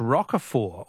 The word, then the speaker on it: Roquefort: The French Roquefort, also with a silent T, is very smelly.